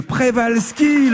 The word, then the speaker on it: Przewalski: C'est le cheval de Prévalski, le nom de ce cheval sauvage. Alors ça s'écrit Przewalski, mais Prévalski, c'était la bonne prononciation.